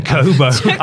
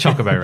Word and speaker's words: Chocobo: Chocobo. – I've purposely mispronounced that. I'm sorry. It's Chocobo racing.